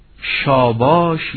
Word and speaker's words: شاباش: این واژه رو شاباش یا شادباش نیز گفتند و هر دو تلفظ و روایت آن نیز بی اشکال است.